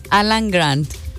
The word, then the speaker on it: Alan Grant: Alan Grant.